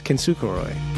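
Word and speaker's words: Kintsukuroi: This one is called, let's see if I can say this right, Kintsukoroi? – That's pretty close. Kintsukoroi.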